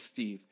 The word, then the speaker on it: Steve: It is Steve. – That is correct. – And if you write a review, goddammit, my name is Steve.